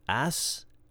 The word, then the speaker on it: Aesculapius: Asclepius. Asclepius. I'm going to spell this for you. A-E-S-C-U-L. A-P-I-U-S.